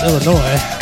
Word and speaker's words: Illinois: I know how to pronounce Illinois.